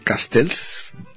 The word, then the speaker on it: Castells: Eh, la cuestión urbana se llama el libro Manuel Castells. Se pronuncia así, pero tiene doble L, como los catalanes.